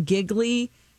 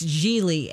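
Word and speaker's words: Gigli: They keep calling him Giggly. And he's like, it's Geely.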